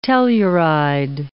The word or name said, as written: telluride